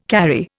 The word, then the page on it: carry: Indian